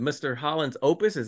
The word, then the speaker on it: Mr. Holland's Opus: Here's Richard Dreyfuss, a guy who you thought, especially after what, Mr. Holland's Opus, am I saying that right? – Yeah, yeah.